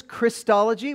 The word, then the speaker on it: Christology: There's Christology, which is the study of? Chris. Yeah, no. Christ, all right, yeah, all right. Chris, it's pronounced Christology, but it's the study of Christ.